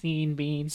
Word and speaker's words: Sean Bean: I believe it's pronounced Sean Bean. – Sean Bean, yeah. My mistake.